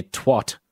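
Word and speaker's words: Twatt: A Scottish council has issued a warning after toxic scum was found near Twat.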